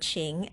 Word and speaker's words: 情: Qing, the pinyin is Q-I-N-G with the second tone put above I. Qing is pronounced this way. Qing, qing, qing qing, second tone, qing.